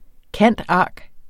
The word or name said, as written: cand.arch.